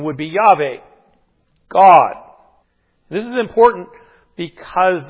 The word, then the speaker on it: Yahweh: And it is best pronounced Yahweh, also known as the Tetragrammaton, a new word we get to learn this year. It simply means those four letters, YHWH. And the vowels are then supplied to come up with that pronunciation, Yahweh.